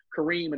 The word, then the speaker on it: Karim: So Kareem, and that's spelled K-A-R-I-M, middle initial R, last name is Ellis, E-L-L-I-S.